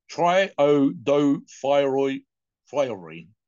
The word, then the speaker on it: Triiodothyronine: Triiodothyronine.